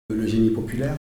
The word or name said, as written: génie populaire